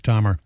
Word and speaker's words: Dahmer: Dahmer.